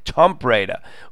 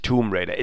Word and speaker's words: Tomb Raider: Und er selbst, und der ist der englischen Sprache wirklich mächtig und ist auch ein Spieleliebhaber, er selbst nennt die Spieleserie Tomb Raider und nicht Tomb Raider. Es gibt einfach Worte, die zwei Aussprachen erlauben.